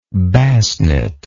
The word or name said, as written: Basnet